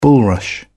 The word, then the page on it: bulrush: British English